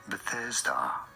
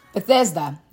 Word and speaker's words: Bethesda: Bethesda. – Bethesda, right.